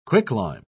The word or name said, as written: quicklime